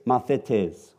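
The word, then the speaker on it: mathetes (μαθητής): So the first one is mathetes, or you can say mathetes, but the way they pronounce it in the Greek is mathetes, okay?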